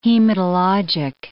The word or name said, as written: hematologic